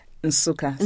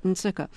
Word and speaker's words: Nsukka: You were born in a town called Nsukka. Am I saying that right? – Nsukka. So the N is hummed. – Yes, Nsukka.